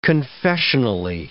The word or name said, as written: confessionally